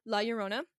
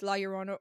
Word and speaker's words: La Llorona: So, La Llorona can be pronounced two different ways. It's La Llorona or La Llorona.